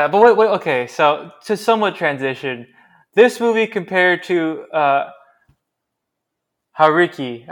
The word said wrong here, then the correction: Harakiri: It's not Hariki. it's Harakiri, my God, I butchered this.